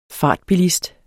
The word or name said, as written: fartbilist